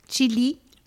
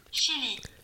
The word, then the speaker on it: Chile: Hier die Übersetzung ist geilerweise Chili. Man spricht es folgendermaßen aus. Chile. Das ist die deutsche Version? Chili.